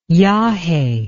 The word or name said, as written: yage